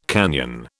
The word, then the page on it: canyon: British English